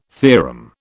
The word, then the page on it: theorem: British English